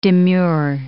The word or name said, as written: demure